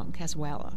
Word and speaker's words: cazuela: cazuela and cazuela. Did I say it right? – Yeah, I think you did.